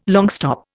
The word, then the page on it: longstop: Indian